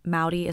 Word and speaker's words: Mowdy: If anyone is wondering, though, Mowdy is my last name. It rhymes with Pouty.